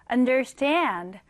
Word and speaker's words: understand: 읽을 때는 understand 뒤에 이렇게 강세를 주시면 됩니다.